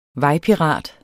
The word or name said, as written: vejpirat